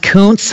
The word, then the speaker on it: Kuntz: It's pronounced, how many times do you think Rusty in his life had to say, it's pronounced Kuntz, thank you very much? – Every damn day.